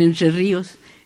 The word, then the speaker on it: Entre Ríos: quiero recordarle al público que nací en Entre Ríos, así se pronuncia ya, en Entre Ríos,